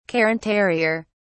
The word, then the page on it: cairn terrier: British English